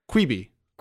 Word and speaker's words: Quibi: And I guess a Quibi show or a Treebie show? – Sorry, what was that, Danny? – Is it a Treebie show? I actually don't know how to say the name. – Quibi.